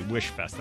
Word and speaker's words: Weishfest: Now, let me spell it for you because it's spelled differently. It's WEISHFEST, Wish Fest.